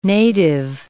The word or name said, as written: native